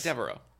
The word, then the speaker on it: Devereaux: Well, like Devereaux, because it's D-E-V-E-R... Yeah. E-A-U-X. – Yeah, Devereaux.